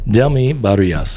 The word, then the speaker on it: Delmy Barillas: We are joined by Delmy Barillas.